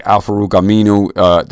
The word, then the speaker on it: Al-Farouq Aminu: Al-Farouq Aminu.